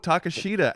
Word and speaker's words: Takeshita: Oh, Takeshita. Actually, it's pronounced Takeshita.